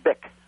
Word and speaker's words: Bich: The family name is spelled B-I-C-H, and for all the obvious reasons, they pronounced it Bick.